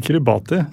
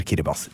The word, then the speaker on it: Kiribati: Ja, det heter jo ikke Kiribati. – Nei, selv om det mange som leser, vil jo anta det selvfølgelig. – Ja, det heter Kiribati. T-I uttales vanligvis, ikke S.